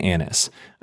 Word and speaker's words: Annas: But John is the only one that highlights he went to Annas. If that's how you say his name, it makes sense to me. But I like that pronunciation.